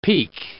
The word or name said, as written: peak